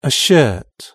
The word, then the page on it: a shirt: British English